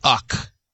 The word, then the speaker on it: UCK: And I have a software pick, and it's Uck.